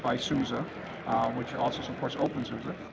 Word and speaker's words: OpenSUSE: Yeah, OpenSUSE.